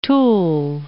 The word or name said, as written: tool